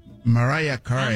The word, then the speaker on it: Mariah Carey: Cristina Aguilera, Mariah Carey, ¿o cómo se dice eso? ¿Sí, no? ¿Mariah Carey? – Mariah Carey.